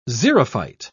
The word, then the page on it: xerophyte: British English